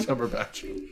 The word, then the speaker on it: Cumberbatch: It's Cumberbatch. You said it right.